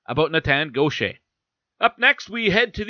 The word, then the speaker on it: Gaucher: But when I asked him, he said it's Gaucher. So I went with that. Because it's not spelled like, it's not G-A-U-T-H-I-E-R, Gaucher. It's G-A-U-C-H-E-R. But I guess it's pronounced the same way.